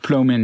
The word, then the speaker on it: Plomin: Ähm, du hast dich mit einem Wissenschaftler auseinandergesetzt, Robert Plomin heißt der habe ich das richtig ausgesprochen? – Ja, ja. Robert Plomin, ja, ja.